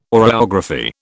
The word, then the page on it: oreography: British English